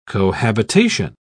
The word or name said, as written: cohabitation